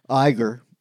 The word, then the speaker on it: Iger: No, I just think, why does Iger, or I can't even say his name, Iger. – Yeah. – As in Sanction.